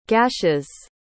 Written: gash's

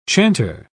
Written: chanter

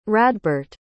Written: radbert

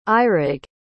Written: eurig

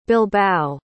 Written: bilbao

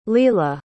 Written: leela

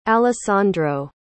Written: alessandro

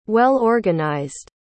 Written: well-organized

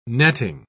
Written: netting

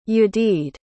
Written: yadid

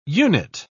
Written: unit

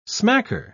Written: smacker

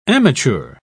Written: amateur

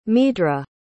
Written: meadhra

